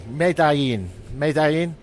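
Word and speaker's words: Medellín: I think it's spelled Medellin, but I think the two L's become a sort of YG. I don't know. Medellin.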